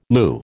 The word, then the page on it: lieu: American English